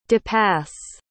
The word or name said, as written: depass